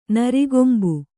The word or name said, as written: ನರಿಕೊಂಬು